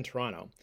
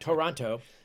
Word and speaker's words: Toronto: And he thinks to himself at that time, with the wind blowing in Toronto, and I pronounce that the Canadian way, by the way. It's T-R-O-N-N-O, Toronto, not Toronto, as Americans pronounce it.